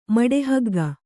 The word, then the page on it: ಮಡೆಹಗ್ಗ: Kannada